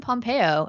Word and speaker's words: Pompeo: Also in that Google search, I found out that Ellen Pompeo, is that how you say her name? – Yep.